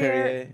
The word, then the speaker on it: Perrier: Perrier? – Perrier.